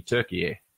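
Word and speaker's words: Türkiye: So they've gone to the word, it's the English spelling of how they pronounce it in Turkish, which is actually Turkey.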